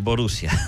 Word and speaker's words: Borussia: Borussia.